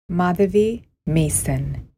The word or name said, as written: Madhavi Mason